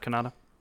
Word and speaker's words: Kanata: Like Jeff Kanata. Spelled differently, but pronounced the same.